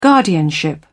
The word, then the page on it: guardianship: British English